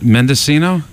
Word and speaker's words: Mendocino: What, Mendocino? – Mendocino by Sir Douglas Quintet.